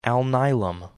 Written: Alnilam

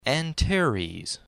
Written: Antares

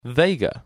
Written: Vega

